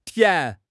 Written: tian2

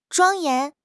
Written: 庄严

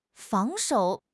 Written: 防守

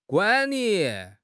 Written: guan3 li3

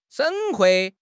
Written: 省会

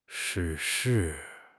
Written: 逝世

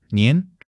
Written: nin2

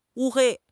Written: 乌黑

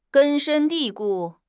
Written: 根深蒂固

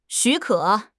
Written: 许可

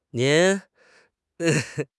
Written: nin2

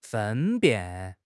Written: 分辨

